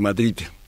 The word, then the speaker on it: Madrid: Aquí en los españoles, para recalcar la D final, suelen decir Madrid, porque a los niños se les enseña que tienen que pronunciar bien la D final de Madrid. Y entonces el énfasis hace que los madrileños le digan Madrid como una especie de Z final.